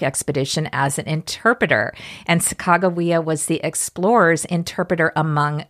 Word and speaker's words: Sacagawea: It's Sacagawea, which is the wrong way to pronounce it, but that's, hey, I went to school in the 1960s and 70s, and back then, they taught us it was Sacagawea. – Right. And now scholars believe that it's pronounced Sacagawea, and the baby was her son, Jean-Baptiste Charbonneau.